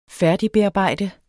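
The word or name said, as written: færdigbearbejde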